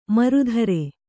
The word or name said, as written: ಮರುಧರೆ